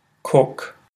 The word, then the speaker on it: cook: I hope it's helped you differentiate between the pronunciation of cook and cock. So it's definitely a good way of training your ears so that you can hear the slight difference in those oh and ooh vowel sounds in English,